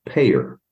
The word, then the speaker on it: PAEER: P-A-E-E-R. Now, you'll get a kick out of this because it's pronounced payer.